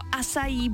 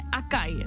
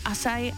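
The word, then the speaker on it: açaí: Asaj nebo asají bowl a ne akaj. Někdo říká acai bowl, tak je to acai, acai.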